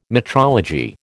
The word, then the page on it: metrology: American English